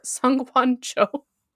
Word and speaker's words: Sungwon Cho: Sungwoo Cho, or Sungwon Cho. I already messed it up. I'm like, I know his name. Prozd. So I said I know how to pronounce his name, and then I pronounced it wrong anyway.